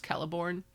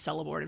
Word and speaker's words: Celeborn: It's Lord of the Rings, and it's Celeborn. – Oh. – I'll die on the hill of anyone who says Celeborn in front of me. Like, actually, it's a hard C.